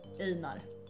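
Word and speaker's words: Einár: Ah, Däremot har vi nu svaret på frågan. Han uttalas Einar. Utan betoning på at.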